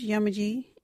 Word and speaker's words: yamaji: local Wadjuri word is yamaji. What is a yamaji? Yamaji is a man. You can use yamaji in this sentence. That yamaji is a stranger. Yamaji. Yamaji is spelt Y-A-M-A-J-I.